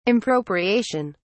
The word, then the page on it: impropriation: British English